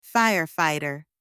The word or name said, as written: firefighter